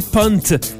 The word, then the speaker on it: Punkt: Si on peut décrire la pochette, si vous ne l'avez pas vue encore à la maison, cherchez Pierre Lapointe, Punkt, P-U-N-K-T, qui apparemment, faut le prononcer Punt, parce que c'est de l'allemand et ça voudrait dire point d'exclamation.